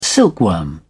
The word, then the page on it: silkworm: British English